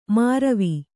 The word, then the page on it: ಮಾರವಿ: māravi